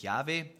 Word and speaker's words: Yahweh: Und man hat dann geforscht, wie das wohl am besten ausgesprochen worden sein kann. Und die meisten Ausleger gehen davon aus, man spricht jetzt tatsächlich Yahweh aus, aber es gibt auch natürlich noch andere Möglichkeiten, das auszusprechen, kennt ihr vielleicht.